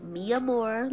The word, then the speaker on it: Mia Moore: Mia Moore, like mi amor, you know, but it's put together.